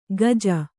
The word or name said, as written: ಗಜ